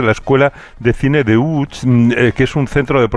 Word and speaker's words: Łódź: Aunque se pronuncia Łódź, se escribe Lodz.